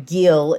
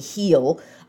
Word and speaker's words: Gil: I've been calling him Gil. It's actually Heel.